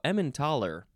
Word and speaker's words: Emmentaler: Next is ementator? No, ementaler. I thought it was ementator for a second. Ementaler, capital E-M-M-E-N-T-A-L-E-R.